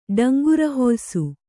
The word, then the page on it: ಡಂಗುರಹೊಯಿಸು: kannada